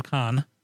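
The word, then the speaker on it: Cannes: Well, I didn't stop you when you said Canes instead of Con for the film festival.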